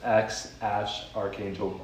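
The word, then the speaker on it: X Æ A-12: I've heard that that can be pronounced as X Ash Archangel Musk.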